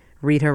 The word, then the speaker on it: Rita: For a copy of the schedule, just write to me, Rita Rochelle. My name is spelled R-I-T-A. Rochelle is R-O-C-H-E-L-L-E.